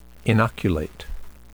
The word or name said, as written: inoculate